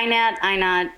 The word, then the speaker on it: Ihnat: It is. It Ihnat? Nobody says it right. – I don't say it right. – It's fine. – I've been freaking out about it for the last 2 hours. So it's Ihnat, right? – Or yeah, Ihnat.